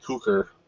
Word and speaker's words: Cukor: I never know if I'm pronouncing his name right, but Cukor.